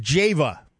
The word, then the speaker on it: Java: And it's pronounced Java. – That is correct. Well, all the locals anyways.